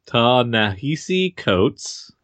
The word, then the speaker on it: Ta-Nehisi Coates: Ta-Nehisi Coates and Brian Stelfreeze.